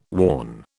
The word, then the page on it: warn: British English